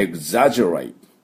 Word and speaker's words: exaggerate: The J rate で、 The J この GE の音は半母音の、あ、で Rate の A は A、投げ飛ばすの A です。だから Exaggerate、 Exaggerate というふうに発音します。